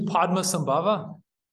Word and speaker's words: Padmasambhava: But oh yeah, his name's Padmasambhava.